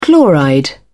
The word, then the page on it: chloride: British English